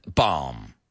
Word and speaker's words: balm: I won't tell her you stole her lip balm. Como balm. B-A-L-M. Balm. La L casi no lo pronunciamos. Lip balm. Sí, no decimos lip balm. Es una L muda.